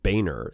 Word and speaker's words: Boehner: Ohio Representative Boehner,